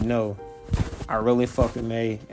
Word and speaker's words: Mai: And with that being said, pretty cool character that I really didn't remember much was Mai. Or Mei, as it's pronounced.